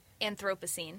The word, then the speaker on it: Anthropocene: Anthropocene. – Anthropocene.